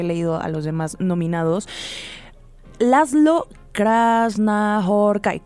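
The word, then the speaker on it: László Krasznahorkai: Pues lo pronunciaste increíble, Laszlo Krasznahorkai.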